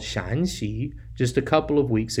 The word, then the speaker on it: Shaanxi: It might be difficult to hear the difference, and that's because the only difference between the pronunciation of these two provinces is the tone. 2 weeks ago, we highlighted Shaanxi with a rising tone for Shan, and this week we're looking at Shaanxi with a flat tone for Shan.